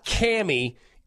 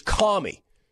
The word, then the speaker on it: Kamala: It's not Cammy, it's Commie.